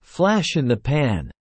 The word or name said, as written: flash in the pan